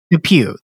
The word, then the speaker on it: Dupieux: Is that how you say it? Dupieux? Something like that? – Dupieux. That's much better than Dupieux.